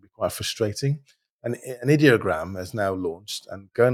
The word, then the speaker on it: ideogram: I pronounce it ideogram, but they say it's ideogram. So ideogram.ai.